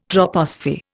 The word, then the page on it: troposphere: Indian